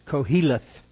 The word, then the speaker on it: Koheleth: The word Ecclesiastes, and I looked, I could not find it, the proper pronunciation is Koheleth, K-O-H-E-L-E-T-H, and it means preacher.